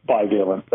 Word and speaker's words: bivalent: So what about this new bivalent booster? How am I doing with the pronunciation, by the way? – It's perfect. You're a pro. Bivalent.